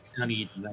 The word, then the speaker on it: Dunedin: This is Dunedin. That's how you pronounce that, right? – Dunedin.